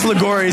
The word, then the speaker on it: Liguori: My last name is Ligori, so people spell it wrong, but they can usually say it.